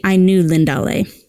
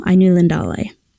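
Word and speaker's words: Ainulindalë: In fact, I said that wrong now that I'm looking at it. The Ainulindale. I left out a syllable. Ainulindale.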